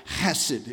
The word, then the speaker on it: chesed: Hesed. Okay, to really say it right, you have to gurgle in the back of your throat. It's really like chesed is how you say it, but I didn't want you to spit on the people around you, so I just thought we wouldn't do that.